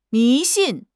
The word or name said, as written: 迷信